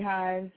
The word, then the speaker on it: Hines: You can find me on Facebook under Ashley Hines, last name is spelled H-I-N-E-S.